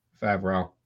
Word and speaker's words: Favreau: What did I say? – Favreau. – Favreau. – That's how you say it.